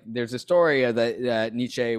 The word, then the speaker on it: Nietzsche: Sorry, Nietzsche.